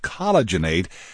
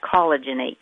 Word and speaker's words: collagenate: So there's an A-T-E on the end, just like rejuvenate, but it's collagenate.